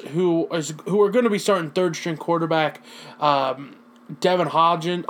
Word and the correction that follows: Hodges: How do you even say the guy's name? Devin Hodlin. It's Devin Hodges, excuse me. That's how you say his name.